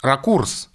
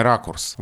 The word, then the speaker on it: ракурс: Будет написано, что правильно, если мы возьмем словарь 50-х годов, например, что правильно ракурс, и не рекомендуется ударение ракурс.